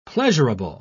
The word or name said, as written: pleasurable